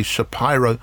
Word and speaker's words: Shapiro: I sometimes say Shapiro, I should say Shapiro, because that's how he pronounces his name.